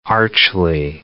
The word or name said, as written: archly